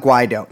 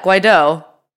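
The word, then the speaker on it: Guaidó: I thought it was, yeah, Guaido. – Guaido. Well, Guaido sounds a little cooler, actually. Maybe I'll do that. – Guaido. Yeah, Guaido. Because the accent, wouldn't the accent, I mean, I don't know. Wouldn't the accent, I couldn't do the accent. – Wouldn't it denote that the emphasis would be on the second syllable?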